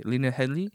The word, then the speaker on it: Headey: How do you pronounce her name? – Lena Headey? – Headey? Okay, Lena Headey. She will be like the headmaster of the school. – Oh, sorry, Lena Headey. – Headey. There's no L with her.